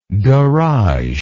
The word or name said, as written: garageable